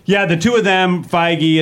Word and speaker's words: Feige: Yeah, Feige.